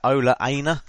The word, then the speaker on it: Ola Aina: And by that, I'm sort of thinking, you know, he gave game time to youngsters like, I don't know if I'm pronouncing this right, is it Ola Aina? Is that how you say his name? – Yeah.